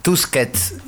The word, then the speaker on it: Tusquets: Eh, Tuckets, Tusquets, siempre lo digo mal,